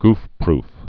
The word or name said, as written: goofproof